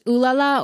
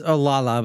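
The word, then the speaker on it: Olalla: Wait, is it actually pronounced Ooh La La or is it Olala? – It's Olala, but everyone calls it Ooh La La.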